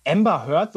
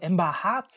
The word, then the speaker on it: Amber Heard: Amber Heard wird doch Amber Heard ausgesprochen, oder? Weil ich höre in Berichten ständig, dass die Leute mittlerweile Amber Hart sagen.